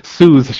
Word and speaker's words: Susse: There's a place called the Swiss Chalet, which oddly enough is spelled Susse. – No chicken. – No, it's Sue's Chalet.